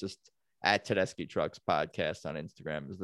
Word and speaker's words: Tedeschi: T E D E S C H I is how you spell Tedeschi. Italian pronunciation, Tedeschi.